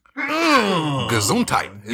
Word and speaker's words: Gesundheit: Gesundheit.